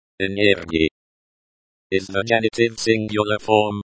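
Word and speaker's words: энергия: Note the stress on the second to last syllable of the word. Energia.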